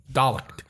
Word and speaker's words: Dalek: Obviously, I couldn't remember if it's pronounced Dalek or Dalek. – Dalek.